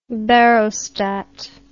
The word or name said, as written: barostat